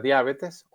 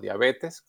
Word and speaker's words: diabetes: Otra causa de enfermedad crónica pues es la diabetes o diabetes, como la quieran llamar, está aceptado en español cualquiera de las dos formas. Usted puede decir diabetes si lleva el acento en la A o si no se lo pone, pues dice diabetes y cualquiera de las dos es aceptada.